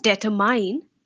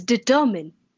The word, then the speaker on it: determine: The first word is determine. Not determine, it's determine.